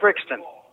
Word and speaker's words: Brixton: My name is Kip Brixton. With ABO. – Your name is what? – Kit Brixton. – Spell it. – A-I-T. B-R-I-X-T-O-N. – Oh, Kit. K-I-T.